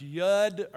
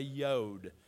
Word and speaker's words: yod: It's pronounced yud or yod. We're going to say yod. Spell Y-U-D.